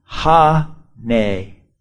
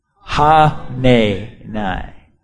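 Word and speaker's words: Hanani: that Ha-na-ha. Let me say it again. I got them all spelled out here phonetically. Ha-ne-nai. Say that with me. Ha-ne-nai.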